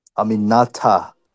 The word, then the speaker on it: Aminata: Aminata.